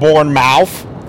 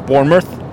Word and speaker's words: Bournemouth: I make fun of the wife all the time with the English teams, but it's not Bournemouth. It's Bournemouth. It's not Leicester.